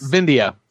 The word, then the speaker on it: Vindhya: Vendhaya? You just were tweeting about this. – Vindhya.